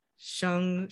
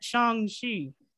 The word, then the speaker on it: Shang-Chi: I said it with a U instead of an A-U sound. – Shang. Shang. Yes, I think you put emphasis on the A in that Shang-Chi. I'm pretty sure that's how you say it.